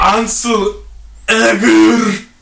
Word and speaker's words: Ansel Elgort: Ansel Elgort. Is that how you say his name? – Yeah. Yes.